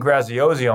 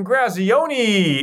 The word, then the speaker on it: Graziosi: When he comes on, I'm like, and we have Dean Grazioni. And he's like, all right, hold up. That's not how you say my name.